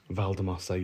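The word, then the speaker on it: Valldemossa: We went to Valdemossa. Did I say that correctly? – Valdemossa, you did.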